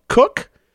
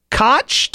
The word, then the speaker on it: Koch: After I learned after marrying into this name that there are actually six different regional pronunciations of K-O-C-H. Cook, to rhyme with book like mine. Koch, to rhyme with crotch, like the former mayor of New York City. I don't get that, but I probably should. Coke.